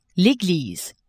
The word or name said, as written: eglise